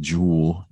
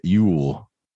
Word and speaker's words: Yule: And Yule, like the Yule log, is J-U-L or J-U-U-L. And you don't say it Jewel, you say it Yule. And it's the J is a Y sound.